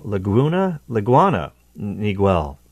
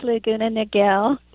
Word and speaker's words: Laguna Niguel: Excuse me, I said that wrong. Laguna, Niguel. I totally butchered that, didn't I? – Yeah, it's Laguna Niguel.